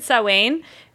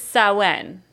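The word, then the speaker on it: Samhain: We said Sawain. It's Sawen.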